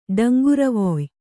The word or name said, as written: ಡಂಗುರವೊಯ್